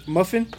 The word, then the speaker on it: MUFON: I guess you pronounce this MUFON. It's MUFON. – Okay. – Is that how you would say that? – Muffin. – Muffin. But there's not two Fs.